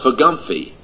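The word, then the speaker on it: FGBMFI: That was when I spoke to the Full Gospel businessman last week, which, by the way, is pronounced Fagumphy.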